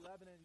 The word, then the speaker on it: Lebanon: It's not Lebanon, and I want you to say it with me. It's Lebanon. Are you ready? Lebanon. Okay, that's how we say it there.